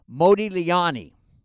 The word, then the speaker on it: Modigliani: By the way, that is the right way to say the sculptor and the painter's name, Modigliani.